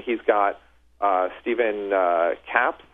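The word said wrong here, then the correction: Kappes: I believe that's how you pronounce his. – I think it's Kappas. – Kappas. Okay.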